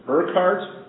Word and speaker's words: Urquhart: Urquhart? – Urquhart.